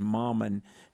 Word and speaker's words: Mammen: It looks like Mammon, but it's pronounced Mammon.